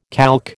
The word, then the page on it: calque: American English